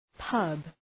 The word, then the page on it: pub: {pʌb}